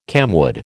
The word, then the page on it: camwood: American English